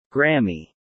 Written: Grammy

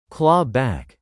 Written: claw-back

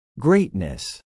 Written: greatness

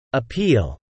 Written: appeal

